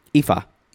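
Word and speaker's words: Ifá: But now she is, it's pronounced Ifa? – Ifa. – Ifa. – Yeah, a Yoruba religion from Africa.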